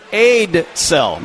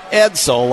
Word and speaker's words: Edsel: Victor Aidsell, not Edsel, like Aidsell.